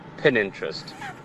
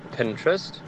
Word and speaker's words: Pinterest: For me, it definitely has to be Pinterest. When people pronounce it Pininterest, the word reads Pinterest.